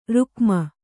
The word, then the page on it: ರುಕ್ಮ: rukma